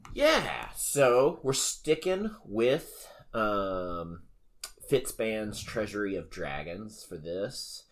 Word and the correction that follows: Fizban: Fizzband. – There's no T in there.